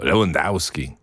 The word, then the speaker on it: Lewandowski: Relacji ze Stanów Zjednoczonych, że Amerykanie przynajmniej wiedzieliby, jak wymawiać nazwisko naszego napastnika. Brzmi ono Lewandowski.